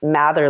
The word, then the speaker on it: Mather: It's Erica Mather or Erica underscore Mather. And I'll spell it because sometimes it doesn't come out right. So it's E-R-I-C-A. And then my last name is Mather, like mother with an A. It's M-A-T-H-E-R.